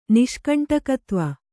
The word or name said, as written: ನಿಷ್ಕಂಟಕತ್ವ